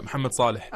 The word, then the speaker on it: Mohamed Salah: Hey, see, you pronounced it right, Mohamed Salah.